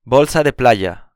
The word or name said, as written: bolsa de playa